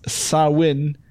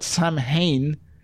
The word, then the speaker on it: Samhain: Looks like I should have stopped one beer earlier, but it's Sawin, so there you are. I always, and this is a peculiar effect about me, I always want to pronounce that Samhain.